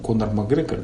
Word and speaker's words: Конор Макгрегор: Конор Макгрегор, да, по-моему, правильно произносится.